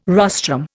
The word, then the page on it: rostrum: Indian